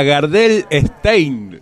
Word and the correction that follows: Garden State: dijo, se llama Gardel Stein, se llama la película. No, Garden State, ahora sí, Leo, tengo que hacer la pronunciación. Garden State.